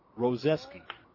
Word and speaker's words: Roszczewsky: They played a little game with me when we were going over the starting lineups of how do you say Trevor's last name? R-O-S-Z-C-Z-E-W-S-K-Y. Double Z's. Roseski.